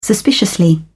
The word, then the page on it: suspiciously: British English